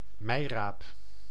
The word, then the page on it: navet: /na.vɛ/